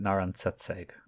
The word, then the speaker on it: Naran Setseg: Then, like, we went up into the bar, which is the Velvet Room, where we met a human woman named Naren Siseg. Naren Siseg. Siseg? – Let me check. – Siseg? You're very close. Yeah, um, I think I was pronouncing it Naran Setseg. – Naran Setseg. That makes that that rolls off the tongue more fluidly than the way that I was just pronouncing it.